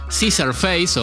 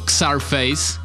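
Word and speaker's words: Czarface: que es una banda que se llama Caesar Face o Xar Face, que se escribe con CZ, que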